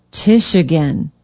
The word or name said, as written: tichigan